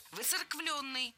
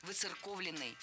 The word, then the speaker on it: выцерковленный: Выцерквленный. Здесь сразу две ошибки в одном слове. Во-первых, пропущено «о». Во-вторых, неправильное ударение. Слово должно звучать так – «выцерковленный». В качестве шпаргалки предлагаю однокоренное прилагательное. «Церковный», «выцерковленный». Далее у нас на очереди краткая форма того же слова.